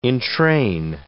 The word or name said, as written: entrain